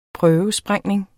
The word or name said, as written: prøvesprængning